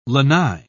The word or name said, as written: lanai